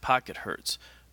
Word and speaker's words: Pocket Hz: I pronounce it Pocket Hertz, but it's spelled Pocket HZ.